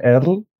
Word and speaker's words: Erle: Erle se llamaba, así se escribía pero creo que se pronunciaba Earl y yo siempre lo pronuncié mal.